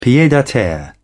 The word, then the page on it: pied-a-terre: British English